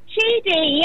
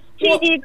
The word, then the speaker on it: Chidi: Chidi, yes, Chidi, you pronounced it very right, yes, I've got it right.